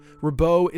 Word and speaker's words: Ribault: Ribaut is spelled R-I-B-A-U-L-T. So it's French. I'm pronouncing it as correctly as I possibly can.